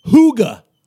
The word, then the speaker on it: hygge: And you've been teaching me a little bit about this thing called hygge.